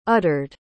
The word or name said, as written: uttered